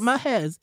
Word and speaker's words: Maahez: I just think of DJ Mehez. Mehez. I still don't know if I'm pronouncing that shit right. – It is. That's how you say it. Mehez. M-A-A-H-E-Z. Mehez.